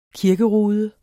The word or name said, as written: kirkerude